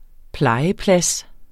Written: plejeplads

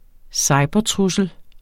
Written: cybertrussel

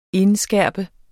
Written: indskærpe

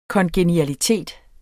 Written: kongenialitet

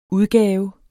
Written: udgave

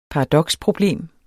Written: paradoksproblem

